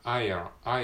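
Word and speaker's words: Ireland: あーんと、どっかに載ってたっけちょっと今発音記号を確認してますけど、えー、っと、あアイアランド、アイアランドか。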